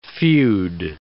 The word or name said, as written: feud